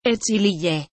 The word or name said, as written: Echlye